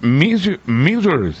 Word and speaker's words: Missouri: Missouri, bien está, así es como se pronuncia, Estados Unidos.